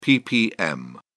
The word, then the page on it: ppm: British English